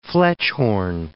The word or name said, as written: Fletschhorn